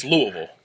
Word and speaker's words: Louisville: But, so Louisville, it's Louisville, right? Is how they say it? Louisville? – Yeah.